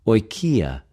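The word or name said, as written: οἰκια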